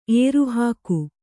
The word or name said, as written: ಏರುಹಾಕು